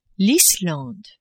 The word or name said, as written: Islande